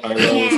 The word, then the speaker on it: Iroh: Iroh is that? – Yep.